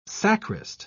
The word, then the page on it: sacrist: British English